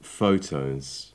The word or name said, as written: photos